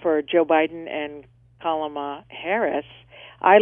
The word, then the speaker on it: Kamala: Comma. Just think of comma, like a comma, an explanation comma. It's Kamala. – Gotcha. Kamala.